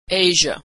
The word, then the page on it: Asia: /zh/